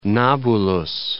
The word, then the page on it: Nabulus: Na.bulus